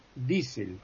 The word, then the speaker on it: Diesel: en alemán es I. Por ejemplo, Kiel, es la ciudad portuaria, es Kiel. Este señor diésel es diésel.